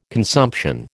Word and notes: consumption: American English

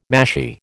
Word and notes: mashie: American English

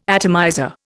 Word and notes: atomizer: Indian

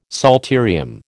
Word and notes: psalterium: American English

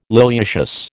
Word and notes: liliaceous: American English

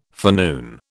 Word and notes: forenoon: British English

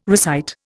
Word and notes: recite: Indian

